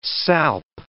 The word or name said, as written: salp